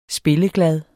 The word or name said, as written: spilleglad